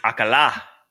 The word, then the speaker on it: Alcala: It looks, the accent is on the last A. So is it Alcala? – Like it's like Bobby Bacala on The Sopranos. Like Alcala.